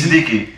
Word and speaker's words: Siddiqui: Siddiqui.